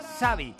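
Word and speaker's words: Xabi: Es un nombre en euskera y su forma de pronunciar es Xavi. – Xavi, no Xavi. – Exacto, y lo estáis pronunciando como el nombre en catalán, Xavi.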